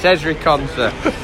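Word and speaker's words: Ezri Konsa: In fact, I got his name wrong. I was reading it and I thought I called him Eric. It's Ezri Conser.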